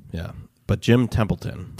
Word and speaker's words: Templeton: So, Jim Templeman. No, that's not how you say it. – Jim Templeton.